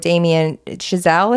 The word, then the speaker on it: Damien Chazelle: And So I read an article by, I think it's Hollywood Reporter with Damien Chazelle, is that how you say his name? – Yeah, yeah.